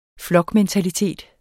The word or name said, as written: flokmentalitet